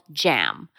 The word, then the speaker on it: GAM: It's called JAM, but is actually spelled G-A-M instead of J-A-M. So it's a soft G.